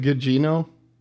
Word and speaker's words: Gugino: Guigno? I am not sure how I'm pronouncing that correctly. – Gugino? Is it Gugino? Carla Gugino? – Yes. Yeah, from Watchmen and the Fall of the House of Usher and The Haunting of Bly Manor.